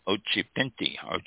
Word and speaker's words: Occhipinti: This is Ariana Ochapintal. I think that's how it's pronounced. O-C-C-H-I-P-I-N-T-I. Ochapinti.